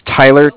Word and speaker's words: Tyler: My name is Tyler, T-Y-L-E-R. – Uh-huh. – I'm calling with Cardiff. It's spelled C-A-R-D-I-F-F, as in Frank.